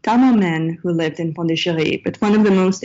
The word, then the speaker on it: Pondicherry: to Pondicherry. And I'll just say in parentheses that I use kind of the French pronunciation of the town because this is what my sources use. Today it's called Puducherry. But